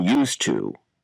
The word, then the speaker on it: used to: Page 65 Pronunciation Sound Reduction Used to Notice how the pronunciation of to in used to changes to t in natural speech.